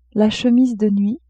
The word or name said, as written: chemise de nuit